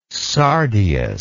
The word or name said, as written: sardius